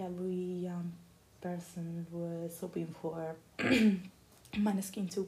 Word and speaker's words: Måneskin: And so Måneskin, that yesterday discovered that correct pronunciation Måneskin,